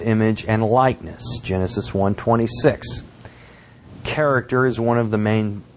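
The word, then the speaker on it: character (χαρακτήρ): The Greek word translated image in the verse is pronounced character.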